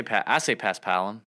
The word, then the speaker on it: Paspalum: I say Paspalum. I guess where you put the emphasis.